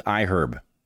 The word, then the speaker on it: iHerb: Our final sponsor for this episode is iHerb. Yep, you pronounce the H in iHerb.